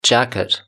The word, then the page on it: jacket: British English